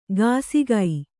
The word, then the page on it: ಗಾಸಿಗೈ: gāsigai